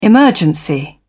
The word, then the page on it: emergency: British English